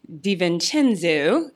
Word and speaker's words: DiVincenzo: The big talk right now, obviously, is Dante DiVincenzo. I believe I'm saying his name right. – You got it.